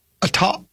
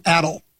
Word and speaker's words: atoll: It's pronounced atoll, not atoll.